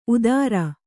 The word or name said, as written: ಉದಾರ